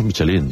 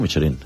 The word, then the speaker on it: Michelin: A ver, aquí en la península ibérica hacemos Michelin. – Sí, bueno, claro, pero la realidad, tú eres un chef internacional. – Ya, pero... – ¿Tú cómo lo pronuncias? – Me cuesta ser tan tontorraco, ¿no? Dice Michelin. – ¿Michelin? – Yo digo Michelin.